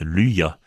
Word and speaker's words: luha: à ne pas confondre avec le verbe luha, briller, qui se prononce luya en léonais.